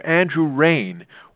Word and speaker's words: Andrew Rehn: Finally, we have Andrew Rain, water resources engineer with the Prairie Rivers Network in Illinois. His name is spelled A-N-D-R-E-W-R-E-H-N.